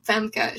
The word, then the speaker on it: Famke: Famke.